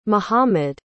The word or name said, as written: mihammad